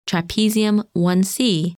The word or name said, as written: Trapezium 1C